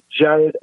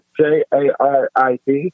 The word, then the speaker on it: Jarid: Yeah, um, you can follow me um, on my Instagram, which is at Jarid Official, and you spell that J A R I D Official.